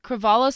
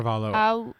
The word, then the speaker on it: Cravalho: I might butcher this, but is it Ali Cravalho? – Cravalho sounds right. – Cravalho.